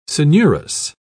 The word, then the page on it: coenurus: British English